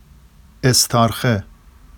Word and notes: estɑrxe: Vafsi

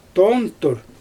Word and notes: toontur: Xyzyl

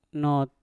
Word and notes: not: Mpur

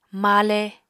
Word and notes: male: apatani

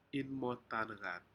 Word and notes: inmotaneat: Aneityum

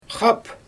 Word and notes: xap: Xyzyl

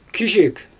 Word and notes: kizhik: Xyzyl

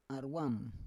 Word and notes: arwam: Roon

